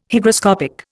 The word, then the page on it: Hygroscopic: Indian